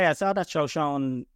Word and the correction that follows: Joe Shane: interview where he talked about, sorry, did I pronounce it wrong there? Joe Shane, is it? – Joe Shane, yep.